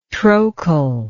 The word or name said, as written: trochal